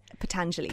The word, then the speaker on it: Patanjali: Patanjali.